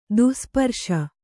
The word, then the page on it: ದುಸ್ಪರ್ಶ: dusparśa